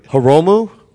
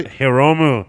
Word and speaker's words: Hiromu: Hiromu. Did I say that right? – Hiromu.